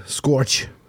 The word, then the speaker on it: scourge: Wie wird das ausgesprochen? S-C-O-U-R-G-E. – Habe ich noch nie gesehen, das Wort. Scorch. – Scorch. Genau.